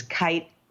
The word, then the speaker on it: Kight: but we're people are tempted to pronounce it Morris Knight, but there is no N and it's a hard K. So it's Kight, like right with a K is how he would say it.